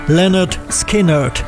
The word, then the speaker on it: Lynyrd Skynyrd: Im Titel der Scheibe liefern sie die korrekte Aussprache ihres Namens gleich mit. Pronounced Lynyrd Skynyrd.